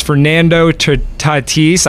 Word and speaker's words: Fernando Tatis: And the one player that I do know other than Shohei Ohtani is Fernando Tatis.